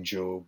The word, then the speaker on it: Jobb: Well, I'm on Twitter at Dean Job. My last name is spelled J-O-B-B.